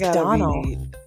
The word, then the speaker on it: Domhnall: I think it's Donald. – That's what I was saying. Donald? – But it sounds like Donald.